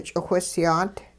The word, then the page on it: ch'iwhisyaat: hupa